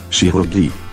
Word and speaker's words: Chirurgie: CH can also have a soft sound, like N, the surgery. – Chirurgie. Die Chirurgie.